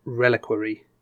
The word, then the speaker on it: reliquary: It's one of those words where, is there a name reliquary. For a word where a bit of it is like another word, like relic? – Yeah. – But you might not necessarily pronounce it like you pronounce that word. You know what I mean? – Yeah. It's like reliquary. Yeah. And you wouldn't say relique.